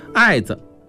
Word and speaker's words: 艾子: 枪剑或水剑，由此引发菖蒲叶子像镇宅宝剑能辟邪，艾子有时口语也读作义子，学名艾蒿又叫艾草，艾子有强烈的气味，可以做药，晒干了点燃了驱虫更佳，同时用来熏身上的穴位，有助于疏通经络，这就是艾灸。